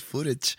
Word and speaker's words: footage: O sea, parece una serie de ciencias de ficción, pero está, está hecho con toda la mano, con fotaje de en aquel entonces, en los ochentas, cuando se establecieron ahí. Fotaje. ¿No se dice eso? Fotaje. – Pues, en inglés es footage.